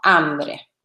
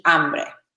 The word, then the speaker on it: hambre: Pues la primera, I'm hungry, sí, se dice tengo hambre, ¿vale? La H es una H muda en español, no se pronuncia. Tengo y hambre.